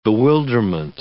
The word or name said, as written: bewilderment